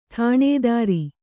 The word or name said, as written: ಠಾಣದಾರ